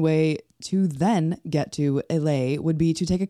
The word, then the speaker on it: Islay: Isle of Islay, I-S-L-A-Y, which the horrible pronunciation is Islay, which is where Lagavulin is.